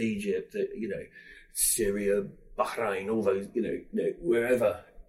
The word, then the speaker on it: Bahrain: Well, I asked that, and you, you did correct my pronunciation, so it's Bahrain. – Bahrain.